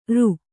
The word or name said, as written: ಱ